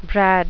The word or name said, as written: brad